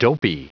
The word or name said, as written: dopey